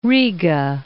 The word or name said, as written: Riga